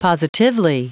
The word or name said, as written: positively